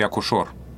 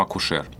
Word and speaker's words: акушер: Вот мне очень нравится пример акушер акушор. А, раньше произносили акушор, После того, как это слово на письме. Так же, как дирижер, монтажер. Да, под влиянием написания изменилось произношение слова. Вариант акушер, который был э, раньше недопустим, стал правильным. И сейчас мы говорим акушер.